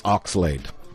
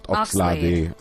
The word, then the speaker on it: Oxlade: Nalumina texted me the correct pronunciation. It's Oxlade, not Oxlade. – Okay. – It is Oxlade.